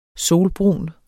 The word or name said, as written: solbrun